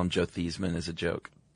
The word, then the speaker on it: Theismann: By the way, you know, Joe Theismann, I don't know if this is true. Supposedly his name was pronounced Theismann until college and he changed it to rhyme with Heisman in a push for the trophy.